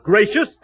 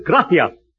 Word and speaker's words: gracias: No, not gracious. That word is gracias. You see, in Spanish, the C is pronounced T-H. Oh. Gracias. You see? T-H.